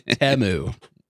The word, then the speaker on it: Temu: It's Temu. I saw so many people commenting about that online. They were like, I just learned that I've been pronouncing Temu wrong for the last year.